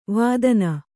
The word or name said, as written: ವೇದನ